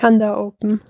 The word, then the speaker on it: Handa Open: Ich meine, du bist ja ein blau-weiß Hand und das Ding heißt dann Handa Open oder Hunter Open? Wie spricht man das aus oder wie spricht ihr das aus? – Die Handa Open.